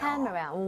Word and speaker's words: camera: Camera 강세 많이들 실수하세요. – 네. – 예요. 여기에만 강세 주면 돼요. – 카메라.